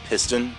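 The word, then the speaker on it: Pist.On: That's a play on words, as the band name is spelled Piston, but pronounced Pist. Like getting peed on.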